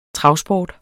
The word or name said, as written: travsport